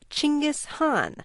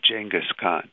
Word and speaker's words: Genghis Khan: The correct Mongolian pronunciation for the name is Genghis Khan, but especially the Hong is too difficult for most people to say. So it's sort of standardized in English as Genghis Khan.